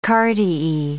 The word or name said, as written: cardiae